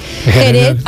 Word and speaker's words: Jerez: Jerez, en principio era con una X, pero ahora se escribe con J y se pronuncia con J. Nadie dice Jerez.